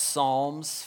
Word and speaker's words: Psalms: All right, Psalm is tricky, starts with a P. It's one of those weird, if you're a teacher, like weird silent P, okay? But parents, just help them out with that. But it's pronounced Psalms 42 and 43, okay?